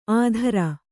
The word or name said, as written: ಆಧರ